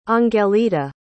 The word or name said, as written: angelita